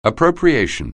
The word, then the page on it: appropriation: anglais